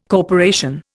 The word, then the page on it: corporation: Indian